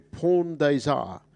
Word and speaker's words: Pont des Arts: There's a first starter that bears mentioning too, Pawn Days Are. That's pretty much how you say it. It's that famous footbridge in Paris. Pont des Arts. Or if you pronounce it as it reads, it's Pont des Arts. We'll go with the French pronunciation.